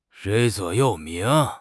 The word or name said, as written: 座右铭